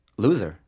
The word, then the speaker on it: Luzer: It's spelled L-U-Z-E-R. – So how did they pronounce it when you were growing up? – Luza.